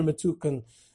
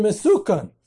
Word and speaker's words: Metukan: So it was called the Cheder Mesukan so the opponents within the Jewish community said, ah, it's a cheder mesukan. It's not with a suf. it's a samach.